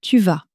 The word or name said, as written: tu vas